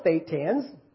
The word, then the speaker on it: thetan: Humanity, according to Scientology doctrine, we were all once thetans. Rhymes with Satan.